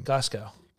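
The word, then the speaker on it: Gossow: Glasgow. There's no L.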